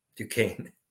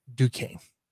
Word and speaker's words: Duquesne: Duquesne. – Duquesne.